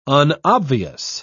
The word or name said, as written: unobvious